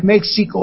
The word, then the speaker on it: México: Prójimo es lo mismo que próximo, únicamente que en un tiempo en castellano la X se pronunciaba J, y ahora se escribe México o México y da lo mismo.